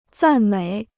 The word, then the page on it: 赞美: zàn měi